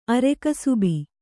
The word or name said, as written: ಅರೆಕಸುಬಿ